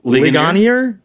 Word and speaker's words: Ligonier: Sproul's uh, uh, it was, it's a, it's a it's like a state of the theology survey from uh, I'm not sure how to say this. It's a town in Pennsylvania, Ligonier. Ligon- it Ligonier. is Ligonier. Ligonier.